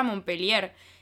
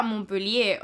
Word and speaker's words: Montpellier: Me quiero volver a Francia, quiero volver a Montpellier. Quiero volver a... Bueno, lo voy a pronunciar bien. Quiero volver a Montpellier, o sea, eh, no quiero seguir acá.